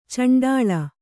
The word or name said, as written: ಚುಂಡಿಲಿ